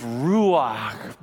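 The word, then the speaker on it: Ruach (רוח): The word is ruach. Say that with me, please. Ruach. The second syllable, put it in the back of your throat. It's kind of a kh.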